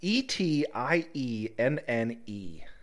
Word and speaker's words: Etienne: E T I E N N E. What the, how the hell do you pronounce that? Etienne. Etienne L. Mc... Ewan. Ewan. E- M-C-E-W-A-N.